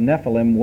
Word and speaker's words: Nephilim: The word for giants is Nephilim. N-E-P-H-I-L-I-M. Or if you want to pronounce it in Hebrew, Nephilim.